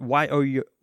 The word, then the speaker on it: Yorkshire: However, if one calls Yorkshire his or her home, it is pronounced Yorkshire. And he says that Yorkshire. So that's the pronunciation style, Yorkshire.